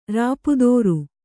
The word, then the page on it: ರೂಪುದೋರು: rūpudōru